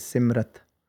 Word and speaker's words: Simrit: A lot of people end up, you know, pronouncing this as Simrit, but it's Simrit. And the Pair De Rare No Vi Apni Sihari Lag Diya. So it's Set and then Mrit, Simrit.